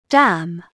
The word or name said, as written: dam